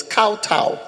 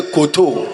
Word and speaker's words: kowtow: It's kowtow. Kowtow. It's kowtow. If you like, press your. You check your dictionary and press. There's a speaker. Press it. You pronounce it to tell you kowtow. And you say koto. You should be saying kowtow.